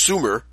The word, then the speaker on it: Sumer: And yeah, that's pronounced Sumer.